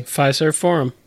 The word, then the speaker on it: Fiserv Forum: Fiserv Forum. Did I pronounce it correctly? – Fiserv Forum. – Fiserv. – That is correct.